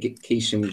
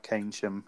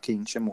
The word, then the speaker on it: Keynsham: I've just sent you a message on how to pronounce it, and you've still gone for Keisham. – I've been saying it forever, like since obviously you moved there. I was just texting you. I've never heard of the place before, so I think all the Devils team have been saying it wrong so far. – There I am, worrying about Keynsham. I should have been worrying about Keynsham all along.